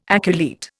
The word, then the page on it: aculeate: Indian